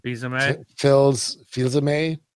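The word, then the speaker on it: Fils-Aimé: Fils-a-may? – Yeah.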